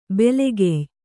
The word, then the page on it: ಬೆಲೆಗೆಯ್: belegey